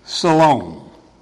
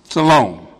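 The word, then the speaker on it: Shalom (שלום): It means, in the Hebrew, it's pronounced Shalom. Shalom.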